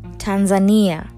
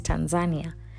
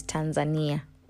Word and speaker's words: Tanzania: Yes, I pronounce that right because what we usually say is Tanzania, and they get really upset when you do that because they are actually a Kiswahili speaking nation. And so, how you pronounce their country name is Tanzania.